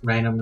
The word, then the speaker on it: Raynham: I think I'm pronouncing that right. Or A-Y-N-H-A-M, Raynham? – I would have pronounced it as Raynham as well.